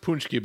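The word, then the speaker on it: pączki: Well, it's pronounced Punchki, but it's spelled Paxi.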